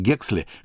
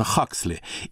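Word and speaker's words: Huxley: Джулиан был родным братом писателя Олдоса Хаксли и единокровным братом нобелевского лауреата физиолога Эндрю Хаксли. В русской традиции, чтобы различать братьев, их фамилии произносили по-разному.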